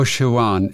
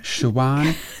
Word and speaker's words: Siobhan: Oh, Shawan. Siobhan Shawan.